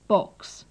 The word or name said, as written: box